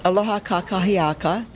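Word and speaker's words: Aloha kakahiaka: Aloha kākahi'a. Gotta pronounce it correctly. Aloha kākahi'aka.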